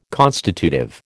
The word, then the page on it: constitutive: American English